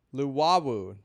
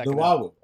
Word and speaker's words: Luwawu: How are you pronouncing that name? – Lualu. Is that now how you pronounce it? – Luwawu. There's no L. – Oh, Luwawu.